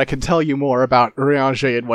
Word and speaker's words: Urianger: Oh, so first off, it's Urianger.